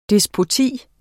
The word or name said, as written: despoti